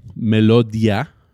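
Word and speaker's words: Melodiya: Empezó a, firmar contrato bajo otra disquera, ah, Melodia. se escribe como Melodía, pero es con una y y al último, di ya.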